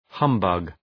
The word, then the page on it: humbug: ‘hʌm,bʌg